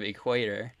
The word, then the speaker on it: equator: And a couple things that I took note of is his pronunciation of Ecuador instead of equator.